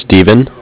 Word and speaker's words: Steven: My first name is Steven,